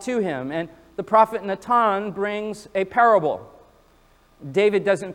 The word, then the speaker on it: Natan: In the Hebrew, his name would be pronounced something like Natan.